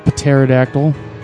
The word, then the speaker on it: pterodactyl: Pterodactyl. – So I guess the T is silent. The P is silent.